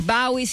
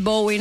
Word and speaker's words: Bowie: aveva autorizzato entrambe le pronunce, proprio visto un'intervista, credo degli anni Ottanta, disse Bowie, Bowie, va bene tutto, chiamatemi come volete,